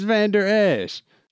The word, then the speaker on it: Vander Esch: It's Vander Esch. Although white people do that with like names like Antetokounmpo all the time. I can't really complain. Keep going. – Vander Esch.